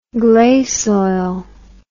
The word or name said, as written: gleisoil